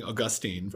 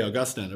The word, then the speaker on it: Augustine: By the way, one of the consequences of my becoming a practicing Catholic is apparently I finally say his name correctly now. I've been saying Augustine for years. Right. And it's like, no. Serious people say Augustine, apparently.